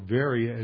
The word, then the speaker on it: Veria: So he was the first convert in Achaia as Paul was moving south from Berea, or Veria as it's pronounced in modern Greek.